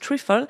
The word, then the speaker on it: trifle: Alors, c'est un dessert à l'anglais, c'est pour ça que je le prononce trifle,